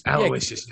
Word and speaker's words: Aloysius: Aloysius, yeah.